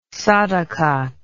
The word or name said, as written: sadaqat